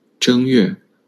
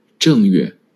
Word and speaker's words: 正月: Option A, 正月. Option B, 正月. Which one is correct? Which tongue is the correct tongue for the first character? – Option A, the first character is pronounced with the first tone.